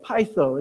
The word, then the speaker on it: peitho: See, this word trust in the original language, it's spelled P-E-I-T-H-O. Pytho is how it's pronounced.